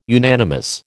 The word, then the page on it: unanimous: American English